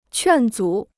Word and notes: 劝阻: quàn zǔ

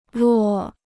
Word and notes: 落: là

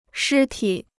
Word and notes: 尸体: shī tǐ

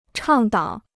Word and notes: 倡导: chàng dǎo

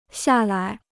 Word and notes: 下来: xià lai

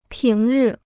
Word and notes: 平日: píng rì

